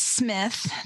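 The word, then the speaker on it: Psmith: My favorite, though, is Smith, The Pea is Silent. He's my favorite. – More fun to call him Pea Smith, though.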